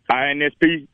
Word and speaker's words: INSP: Which I think when we tune in, which we should tune in, it's coming up in September on the, am I saying it right, INSP network, or is there kind of a shorter term for it? How do you promote the channel? – That's it. INSP, the Inspirational Network.